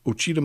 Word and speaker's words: Ouachita: Representative Justin Humphrey's district includes the heavily forested Ouachita Mountains in southeast Oklahoma, and I do think I pronounced that right.